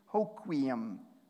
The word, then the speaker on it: Hoquiam: And the first thing is how to pronounce it. Hoquiam.